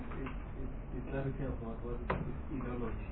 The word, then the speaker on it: enology: onology. – That means the comes from to brew. It is fermentation. Fermentation. – Yes. – How do you pronounce that? – Enology.